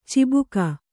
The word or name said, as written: ಚುಬುಕು